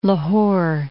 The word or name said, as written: Lahore